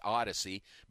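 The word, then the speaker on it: Odicci: It's O-D-I-C-C-I, but it's pronounced Odyssey.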